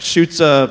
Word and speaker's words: Schutze: He said it's like Schutze arrow or Schutze gun. That's how you pronounce it.